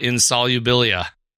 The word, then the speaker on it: Insolubilia: Well, tell me something about Insolubilia.